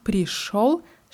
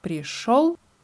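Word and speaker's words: пришёл: Und das Wort пришел schreibt man mit jo obwohl es wie O ausgesprochen wird.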